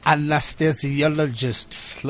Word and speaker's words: anesthesiologist: You are, how do you say this name? Anesthetist. – Anesthesiologist. – Looks like there's anesthesia somewhere there in the middle.